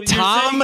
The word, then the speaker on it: Thom: Do you think that when people spell their name T-H-O-M that it's pronounced Thom? – Thumb. Like what I have on my hand. – His name is Thumb Matthews? – Maybe. I don't know. – What? Thumb? – T-H-O-M. That's just Tom.